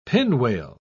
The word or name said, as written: pinwale